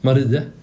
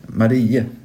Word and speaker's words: Marie: Här blev jag lite osäker på uttal. Jag uttalade det fel. Du uttalade det bättre än jag. – Marie. – Marie, just det, precis.